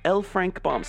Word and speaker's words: L. Frank Baum: All right, here we go with L. Frank Baum, said it right this time, Road to Oz.